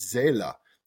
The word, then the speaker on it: Seele: which is pronounced Seele, capital S-E-E-L-E, which is this German word for the mind.